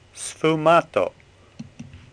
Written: Sfumato

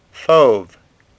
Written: Fauve

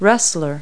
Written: wrestler

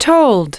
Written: told

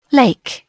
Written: lake

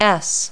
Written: s

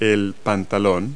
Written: pants

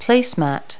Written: placemat

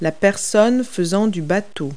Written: boater

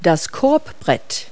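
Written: backboard

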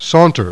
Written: saunter

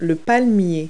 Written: palm tree

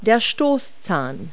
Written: tusk

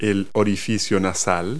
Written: nostril

